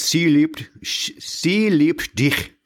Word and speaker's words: sie liebt dich: I've heard it pronounced a couple of different ways. sie liebt dich. So yes, she loves you, but uh, in German.